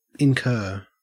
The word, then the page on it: incur: Australian English